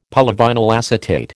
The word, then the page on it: polyvinyl acetate: American English